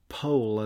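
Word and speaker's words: Powell: It's a book called The Dance to the Music of Time by Anthony Pohl, as I think he pronounced his own name.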